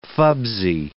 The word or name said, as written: fubsy